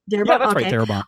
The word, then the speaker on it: Darabont: However, Frank Darabont, am I saying that right? – Darabont.